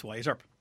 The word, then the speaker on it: Wyatt Earp: Yes, Wyatt Earp.